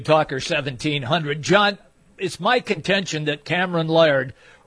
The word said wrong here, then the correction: Lard: or Lard, how do you pronounce it, just so I say it correctly? – Lard.